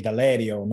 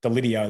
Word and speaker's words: Deledio: And he's like, that second E is like an A. So it's actually Deledio, not not Deledio, like we say here in Australia.